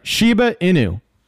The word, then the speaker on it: Shiba Inu: So there's a token called Shibu Inu. Inu. I believe that's how you say it. Or no, I'm sorry. Shiba Inu. Shiba Inu.